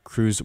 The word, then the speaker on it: CHRU: My set is about over, but before I play one last song, the Carolina Harm Reduction Union, known as CHRU, it's pronounced CRU, is a student-led program that provides life-saving harm reduction resources to the public of Chapel Hill.